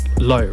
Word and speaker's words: LHO: Yeah, so before my name now is Low, right? So LHO, it's pronounced as Low.